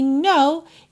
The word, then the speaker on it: know: Know, atenção que o K inicial é mudo, right? A palavra know, saber, conhecer, K-N-O-W, soa igual a palavra know, não, N-O. Right?